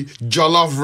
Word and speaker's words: jollof: Oh my God, go for the jollof rice. Jollof rice, is it? – Spell it for me. How do I? – J-O-L-L-O-F. J-O-L-L-O-F, jollof, then rice.